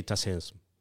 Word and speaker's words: TACEESM: How do you pronounce it? – What is the High Technical School of Architecture? – Yeah, this transforming architectural and civil engineering education. – We say TACEESM.